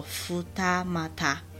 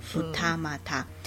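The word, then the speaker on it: 二俣: 二谷站在日文念作福他马他，好，福他马他。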